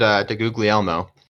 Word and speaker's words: DeGuglielmo: Yeah, we had DeGuglielmo. Can't believe I pronounced that correct the first time. proud of myself. – Name? Wait, dude, that was actually how you pronounce his name? – Yeah. Yeah, it was.